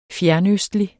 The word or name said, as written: fjernøstlig